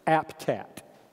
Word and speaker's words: APTAT: acronym which I pronounce APTAT, A P T A T.